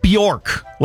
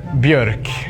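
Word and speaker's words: Björk: It's Bjork. Do like this. Bjork. Bjork. It's hard when you don't have those letters.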